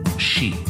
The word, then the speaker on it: sidhe: which is spelled S-I-D-H-E, or I think in Scots English it's S-I-T-H, but it's pronounced she, like banshee.